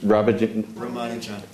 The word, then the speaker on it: Ramanujan: Ramanujan.